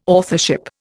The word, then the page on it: authorship: Indian